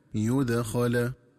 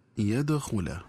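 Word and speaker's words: يُدخَل: لذلك ينبغي التنبه للنطق الصحيح لكلمة يدخل، فهي تنطق بضم الياء وفتح الخاء هكذا يدخل، ومن الخطأ قراءتها بفتح الياء وضم الخاء هكذا يدخل.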